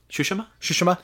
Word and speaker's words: Tsushima: How do you say it? – Tsushima.